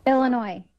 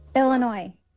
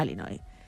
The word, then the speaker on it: Illinois: Illinois. – Vamos a poner la parte corta. – Illinois. – Illinois. No Illinois. Bien.